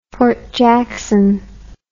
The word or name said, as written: Fort Jackson